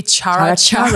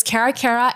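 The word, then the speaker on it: Caracara: C-A-R-A. So if you guys are Malaysian, you'll probably say Chara-Chara. That was my first time pronouncing it. I said it that way. But yes, it's Karakara.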